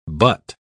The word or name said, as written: butt